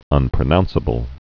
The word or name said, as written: unpronounceable